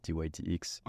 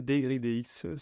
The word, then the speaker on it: dYdX: Alors, est-ce qu'on le prononce d'ailleurs DYDX, DYDX? – Un peu. En français, DYDX, si on préfère, sinon Ok. – DYDX en anglais.